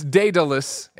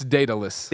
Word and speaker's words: Daedalus: Now Evan says it's Daedalus. It rhymes with Icarus, and it is Icarus's father. So Icarus, Daedalus.